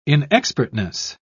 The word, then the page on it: inexpertness: British English